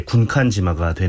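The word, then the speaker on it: 군칸지마가 (軍艦島): 이걸 일본식으로 읽으면 이제 군칸지마가 되는 거죠.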